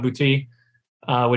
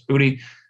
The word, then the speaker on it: Boutte: And the other two things, and we kind of briefly touched on it, Kayshon Boutte, which pains me to say his name that way. He says that's how he says it, but it's Booty or Boutte. That's how it should be.